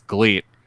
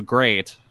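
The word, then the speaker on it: GLEAT: So that's why it's Gleet. They always pronounce it as great, but they don't seem to correct anybody that says Gleet. So everyone just kind of says Gleet because it's more fun.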